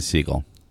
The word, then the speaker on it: Segale: Segale owned the NPR was pronouncing Siegel. – Is it really? – Yeah. – Siegel.